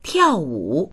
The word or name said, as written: tiao4wu3